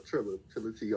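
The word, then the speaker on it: Trilla T: But my rap name, you know, Triller T all day, every day. – Can you spell that for us? – T-R-I-L-L-A, space, capital T.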